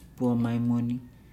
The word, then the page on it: bua maimuni: Nukuoro